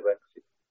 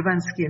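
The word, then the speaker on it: Banksy: Sim, às vezes induzem erro a pronúncia correta, não é? Mas é Bansky. – É Bansky, não é? – Sim.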